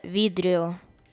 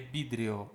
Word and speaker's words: vidrio: La mesa es de vidrio. – De vidrio. В не читаем. У них В произносится как бы. La mesa es de vidrio.